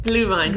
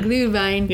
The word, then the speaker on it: Glühwein: How do you say it? – Glühwein. – Glühwein. – Yeah.